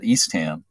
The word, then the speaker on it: Eastham: Interestingly, on Cape Cod, you've got Chatham, but you also have Eastham, and people exactly. – there do pronounce it Eastham.